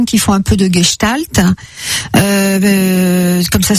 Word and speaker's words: Gestalt: Les gestaltiens, c'est-à-dire des gens qui font de la gestalt, c'est une méthode un peu en psychothérapie. Ça s'écrit GESTALT. Gestalt.